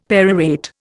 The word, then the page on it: perorate: Indian